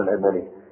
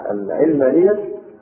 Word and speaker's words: العلمانية: فكلمة العلمانية مش مأخوذة من العلم، مأخوذة من العالم، العالم، العالم. واضح؟ فأدق النطق أن إحنا نفوت عليهم الفرصة، وبدل ما نستعملها بالكسر العين اللي يخدعوا الناس ويظنون أن العلمانية مأخوذة من العلم. لا، هي مأخوذة من كلمة العالم.